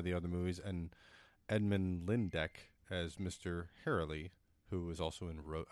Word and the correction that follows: Herlihy: Herlihy, whatever his name Herlihy.